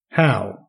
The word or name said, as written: how